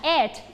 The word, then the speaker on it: eight: พร้อมกัน eight ไม่ใช่เอกนะบางคนออกเสียงเอกเอกอันนั้นคือคือใครเอกใครเลยใช่ไหมเอกแต่คำนี้ออกเสียงลงท้ายด้วย letter t ค่ะ